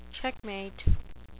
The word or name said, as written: checkmate